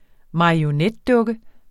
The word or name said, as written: marionetdukker